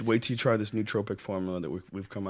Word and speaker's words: nootropic: You take it at night, and the other one is just uh, it like I don't know if you'd call it a nootropic nootropic. How do you say it? I think it's nootropic. – Uh, yeah, nootropic.